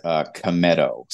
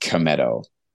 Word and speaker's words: Cometto: it's pretty much like it's spelled, Cometto. So the if I had to spell it phonetically, there'd be an A between the K and the M.